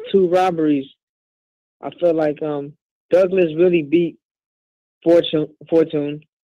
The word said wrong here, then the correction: Fortuna: I pronounced that right. – Fortuna.